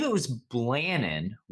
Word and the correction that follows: Blanton: I believe it's pronounced Blanton.